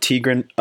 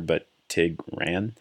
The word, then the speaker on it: Tigran: T-I-G-R-A-N is Tigran. I'm sorry if I'm pronouncing his first name wrong. And then Hamasyan, G-R-A-N, like tiger, but Tigran.